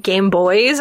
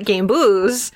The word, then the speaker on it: Gambouz: And I was like, no, I'm not putting that in there, because I'll say Game Boys and not Gambouz, which is apparently how it's supposed to be pronounced, according to Google.